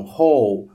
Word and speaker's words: whole: Nesse caso, para essa palavra, eu não vou pronunciar o WH do jeito que a gente viu, é uma exceção, whole, ok? Que é parecida com a palavra buraco, hole, hole palavra buraco e inteiro, eu vou usar a mesma coisa em inglês e é exceção, tá?